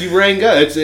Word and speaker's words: Uranga: There's not a fucking O in there. – Yeah, it's Uranga. – It's Uranga. It's spelled exactly how it sounds.